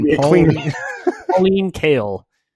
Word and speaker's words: Pauline Kael: I think that maybe it's unfortunate, and maybe I don't know if Pauline Kiel mentioned this, but like it is kind of frustrating that movies are. Did I mispronounce her name? You're narrowing your eyes at me. Okay, it's Kale.